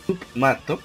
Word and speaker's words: Submato: es el hecho de, un youtuber que se llama Submero, Mato se escribe, Submato,